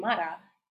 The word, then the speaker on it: Mara: That's how you pronounce my name, Mara. If you don't know how to roll your tongue, that's not my problem.